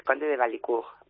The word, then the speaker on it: Conde de Valicourt: Pues Cava Conde de Valicourt, ¿se pronuncia así? – Sí, bueno, es francés, Conde de Valicourt, pero sí.